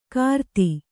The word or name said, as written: ಕಾರ್ತಿ